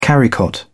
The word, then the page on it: carrycot: British English